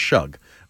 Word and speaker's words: Shough: Tyler Shug. I think that's how you say it, right? I just don't want to say Shug with S-H-O-U-G-H, but I think it's Shug.